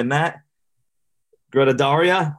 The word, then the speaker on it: Gruttadauria: Today, we're joined by Matt Gruttadauria.